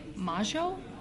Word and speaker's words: Majo: I believe you pronounce it like Majo. It's like M-A-J-O.